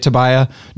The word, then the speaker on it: Tobia: So like it's fine, you know, like the the pronunciation like it I say it Tobiah. Am I even saying it right?